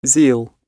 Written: zeal

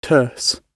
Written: terse

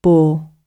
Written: boor

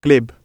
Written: glib

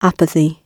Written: apathy

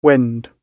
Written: wend

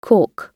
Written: caulk

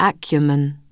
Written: acumen

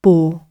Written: bore

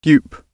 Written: dupe

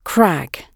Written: crag

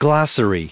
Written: glossary

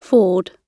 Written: ford

